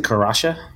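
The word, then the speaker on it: Kasatha: How do you pronounce them? – I pronounce it Karasha.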